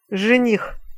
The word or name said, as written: zenih